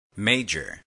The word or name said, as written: major